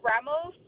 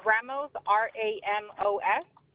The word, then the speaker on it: Ramos: It's Dulce Ramos. – I'm sorry, spell it first name. – D-U-L-C-E. – Last name? – Ramos, R-A-M-O-S. – R-O-M. – R-A-M-O-S.